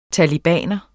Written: talebaner

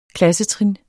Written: klassetrin

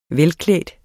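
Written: velklædt

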